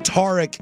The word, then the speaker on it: Tarik: But Tarik is the traditional pronunciation.